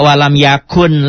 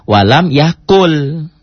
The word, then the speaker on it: walam yakul: Jadi dibacanya enggak walam yakun lagi, tapi walam yakul gitu. Walam yakul.